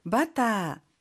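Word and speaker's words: バター: 바타, 버터구요. – 치즈, 치즈, 치즈입니다. – ジャム. – 잠을 잼인데요. 선생님 혹시 발음 팁 있을까요? – 버터는 모음이 완전 다르니 주의해 주시고요.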